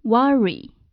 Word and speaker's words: worry: 这个单词呢，实际上按照音标的标准读法的话，可能会读成 worry，就是这个 o 呢要读成啊。但是在美式的口语当中，很多的这个啊的音会读成 a，worry，worry。